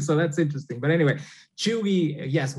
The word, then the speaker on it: cheugy: This first one, Cheugy. And that's spelled Cheugy, Cheugy. What is Cheugy, and am I saying it correctly? – That's correct.